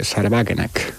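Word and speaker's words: Sahra Wagenknecht: Porque hay, para mí, el experimento de partido más interesante que se está dando en la Unión Europea, por cierto, también putinista, es el de Sarebagenek, o como se diga en alemán, ¿no? – Está bien pronunciado, ¿eh? – Sí. Bueno, pensé que me ha salido demasiado sajón, pero vale.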